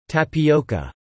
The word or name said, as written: Tapioca